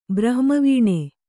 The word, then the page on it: ಬ್ರಹ್ಮವೀಣೆ: Kannada